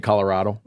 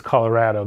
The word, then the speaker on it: Colorado: Locals will say, well, of course it's Colorado. That's how I've always said it. I grew up with Colorado. That's what we say. It sounds right. It's our state. We have the right to decide and to tell you how to say it. You're the new ones, right? – Newcomers are more likely to hew closer to the Spanish pronunciation. – Colorado, you don't say avocado, right?